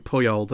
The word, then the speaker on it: Puyol: He's just like, I hope I'm saying that right. Puyol, is it? – Carlos Puyol. Puyol.